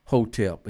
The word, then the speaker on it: HTP: But for those who don't know, talk about the significance and the sacredness of the word HTP, which is pronounced Hotep,